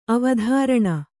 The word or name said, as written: ಅವಧಾರಣೆ